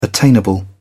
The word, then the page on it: attainable: British English